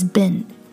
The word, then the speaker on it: been: Jialun and I, we already talked about the correct pronunciation of B E E N is bin. So it sounds like B I N, but it's really spelled B E E N.